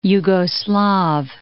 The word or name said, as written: Jugoslav